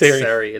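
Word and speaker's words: Seri: Siri? I have to mispronounce things because Jay's not here. – I think it's Siri. It's S-E-R-I. I would say Siri.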